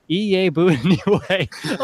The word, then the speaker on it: Iyiegbuniwe: It is going to be pronounced Iyiegbuniwe. Okay, so hold on. I didn't mean to let. Iyiegbuniwe.